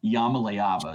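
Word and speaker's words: Iamaleava: Yamaleava is how you say his last name.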